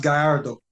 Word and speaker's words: Gallardo: It's pronounced Gallardo, by the way. – Thank you. – Just saying Gallardo, Escobar Gallardo.